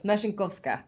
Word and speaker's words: Mesinkovska: I actually know her, so it's Mesinkovska. It's like a machine.